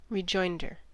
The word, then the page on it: rejoinder: American English